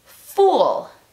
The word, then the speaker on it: fool: La primera palabra es fool. Casi como una U en español.